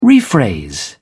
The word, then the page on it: rephrase: British English